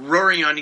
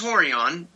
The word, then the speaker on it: Rorion: I read out Rorion. He goes, it's pronounced Horion, but give him a call.